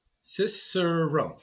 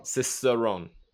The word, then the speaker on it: Cicerone: Cicerone. – Cicerone. – Think of like sis and sister, sis and sister. Sir, like, hello, sir. Rome. – Okay, so Cicerone.